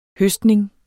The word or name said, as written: høstning